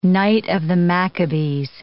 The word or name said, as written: Knight of the Maccabees